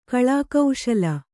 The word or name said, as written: ಕಳಾಕೌಶಲ